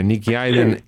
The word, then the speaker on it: Nicky Hayden: Sì, ho detto bene, cioè Nicky Hayden sì.